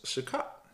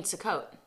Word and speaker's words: Sukkot: Another one that it has listed on here that I thought was pretty cool was Sukkot. – Oh. – Yeah. – You mean Sukkot.